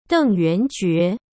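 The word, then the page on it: Deng Yuanjue: Chinese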